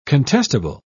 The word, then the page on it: contestable: British English